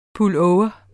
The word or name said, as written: pullover